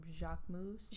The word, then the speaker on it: Jacquemus: Looks like the I'm going to completely butcher that name, Jacquemus. – Jacques, no, that's right.